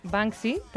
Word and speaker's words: Banksy: Con su director Banksy, también lo he dicho bien, que se ríen del negocio del arte.